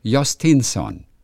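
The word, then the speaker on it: Jhostynxon: Ja, Y-N-X und O-N hinten. Und also ich lese es mal vor, Jostinson. – Genau, aber das wird gar nicht wie X ausgesprochen, sondern wie Jostinson.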